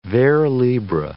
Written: vers libres

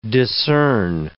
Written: discern

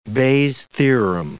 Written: Bayes' theorem

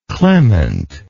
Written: clemently

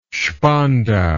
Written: Spandau

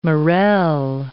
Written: morel

